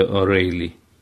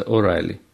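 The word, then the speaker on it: O'Reilly: Здесь в американской нотации, если пишутся две буквы «и» «ай», которые присутствуют в слове О'Рейли, как вы думаете, они в Америке читаются как «ай», не «эй», а «ай». Поэтому читается О'Райли.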